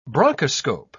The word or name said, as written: bronchoscope